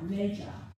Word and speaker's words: magi: And as we just sang, they're three kings, but we know from the scriptures that they were magi, I've got to say it right, magi, which is wise men.